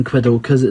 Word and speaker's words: quokkas: It's Q-U-O-K-K-A-S. So however you pronounce that, I'm saying quidokas. It's probably more like quokkas or something like that.